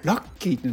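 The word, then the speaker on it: 楽喜: でこれ漢字でね、こう楽しい、喜ぶって書くと、これでラッキーって読むんだと思って、ちょっとね衝撃でしたね、自分の中で。そうか、ラッキーっていうのは楽しくて喜ぶことなんだ。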